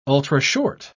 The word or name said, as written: ultrashort